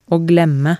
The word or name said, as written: å glemme